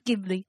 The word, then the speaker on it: Ghibli: Ghibli.